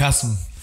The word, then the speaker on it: JSM: Eh, Hasm, sí, Hasm. DJ Hasm.